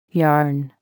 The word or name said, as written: yarn